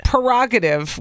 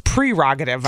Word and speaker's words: prerogative: I thought prerogative was prerogative, but it's prerogative, I've learned.